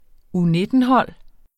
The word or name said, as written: U19-hold